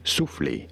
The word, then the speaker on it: soufflé: Soufflé, for reference in French, it is said as souffle. Souffle. In English, however, souffle is how it is said in English.